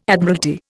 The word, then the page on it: Admiralty: Indian